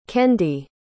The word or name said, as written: kendi